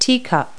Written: teacup

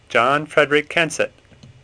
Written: Kensett

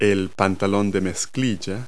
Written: jeans

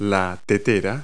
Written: kettle

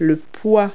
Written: weight

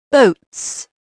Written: boats

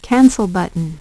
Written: cancel button

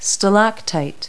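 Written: stalactite

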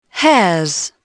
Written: hairs

